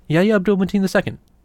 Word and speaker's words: Yahya Abdul-Mateen II: Yahya Abdul-Mateen II.